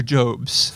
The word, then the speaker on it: Jobs: Is it pronounced Jobs or Jobs? – Jobs.